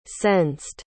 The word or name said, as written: sensed